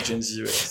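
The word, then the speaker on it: Jen Zee: Du coup ils vont contacter Jenzi. Euh, ah, je sais sais pas si la prononciation c'est comme ça, mais au pire elle m'en voudra pas. – Oui, si, Jenzi.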